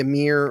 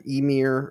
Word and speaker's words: Ymir: This creature, the Ymir or Ymir or however you want to pronounce it. – Ymir, I've heard it's been, I've seen it pronounced and heard it pronounced several different ways.